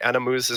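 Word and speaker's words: Ana-Mouz: The other one is, I'm butchering this name, Anamuz. – Sounds about right to me. That sounds right. Anamuz is right to me.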